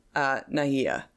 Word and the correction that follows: Naga: That's Nagas. – Nagas. Am I mispronouncing it? – Yes. – What? Naha? – It's Naga. – Naga. – N-A-G. – Oh, like, they spelt right. It with a J, so then I pronounced it like an H.